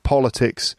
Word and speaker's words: politics: Politics is the first syllable, has the stress. Politics, right, that's the world of politics.